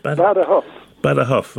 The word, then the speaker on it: Badajoz: Badajoz, right? – Yeah, Badajoz, but it's Badajoz.